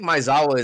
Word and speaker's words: Maezawa: No, I think Maezawa is actually correct as fuck.